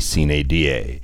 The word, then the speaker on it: sine die: the correct pronunciation would be sine die.